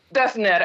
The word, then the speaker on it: Döpfner: Il CEO del gruppo è Matthias Döpfner, che spero di aver pronunciato bene. – Döpfner, ecco, finalmente.